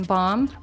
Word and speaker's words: bomb: That B in bomb? – Bombed. – Exactly. We do not pronounce the B.